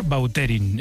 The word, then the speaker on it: Vautrin: Es que a mí me suena mejor Bauterín. Es como... – Da más fuerza, ¿no? – Más puede repetir más la pronunciación. – Bauterín. Sí. Elegante. – La original francesa, Vautrin.